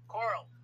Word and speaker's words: Coral: Coral or corral? – Coral. – Coral. Cape Coral, Florida.